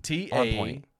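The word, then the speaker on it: Takanakuy: Santo Tomas, and they celebrate what is called Tananukui. – Nailed it. The pronunciation. – Tanakanakuy. – Okay. It's, as far as I know, you got it right.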